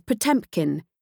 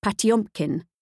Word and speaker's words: Potemkin: The subject of this book is Potemkin, even though in Russian the pronunciation is closer to Potemkin.